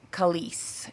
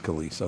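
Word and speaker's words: Kelis: Kellis is how you say it. It is Kellis, okay.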